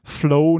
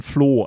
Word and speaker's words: Flow: Es geht um den Flow, nicht den Floh. Also nicht das Tier, sondern Englisch den Fluss.